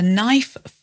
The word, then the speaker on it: knife: Well, a knife, fork, or spoon. That is a standard table knife. That's K-N-I-F-E. Note the silent K there. A fork and a spoon. That's S-P-O-O-N.